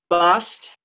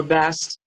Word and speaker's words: Bast: And BAST, or BAST, you say BAST, BAST, BAST. And well, no, some people say BAST, but I found that in this case, the ah sound is very significant in the pronunciation of her name for metaphysical reasons.